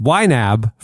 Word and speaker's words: YNAB: It's YNAB, pronounced YNAB for short.